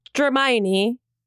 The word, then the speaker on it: Hermione: This is Hermione. – Hermione. Yes. Oh, you said it right.